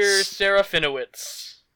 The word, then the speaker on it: Serafinowicz: Peter Serafinowicz.